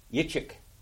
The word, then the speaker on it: Yitzchak: Yitzchak, Yitzchak, Yitzchak, Yitzchak, Yitzchak. What does it mean? – Joy, laughter. – Joy, laughter, pleasure. That's what it means. Joy, laughter, pleasure. That's what Yitzchak means.